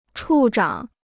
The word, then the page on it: 处长: chù zhǎng